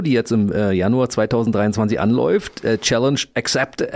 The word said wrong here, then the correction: accepted: Accepted, mhm. – Accepted. Mann, schon tausendmal ausgesprochenes Wort und immer falsch, weißt du?